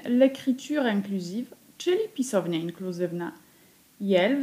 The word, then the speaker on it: iel: Różnice widać tylko na piśmie, w wymowie nie ma żadnej, niezależnie od pisowni i od liczby wymawiamy ten zaimek tak samo, czyli jel.